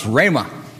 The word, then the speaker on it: rhema: There's a Greek term spelled R-H-E-M-A. It's on the front of your bulletin. Titling, or pronounced rhema.